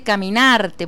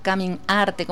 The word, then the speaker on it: Caminarte: Servando, tengo entendido que Caminarte, porque así es que se pronuncia, no Caminarte, como yo lo separaba,